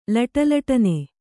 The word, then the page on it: ಲಟಲಟನೆ: Kannada